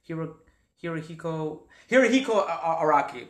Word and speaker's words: Hirohiko Araki: Am I pronouncing it right? Hirohiko Araki. Okay. – That's, yeah.